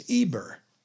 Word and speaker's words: Eber: And if we keep going back, Abraham is related to a man whose name is Eber. And it has an H, but you don't pronounce it. So we don't say Hebrews today. We say Hebrews, but it's the same person.